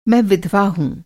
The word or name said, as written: विधवा